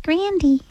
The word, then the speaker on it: Grande: It's actually pronounced Grandy.